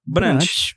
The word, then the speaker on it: Brandt: Eu vou indicar Cabras da Peste do Victor Brandt. Brandt, Brandt, Brandt. Pô, como é que fala o nome dele? Brandt. – Brant.